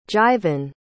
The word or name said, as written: jivin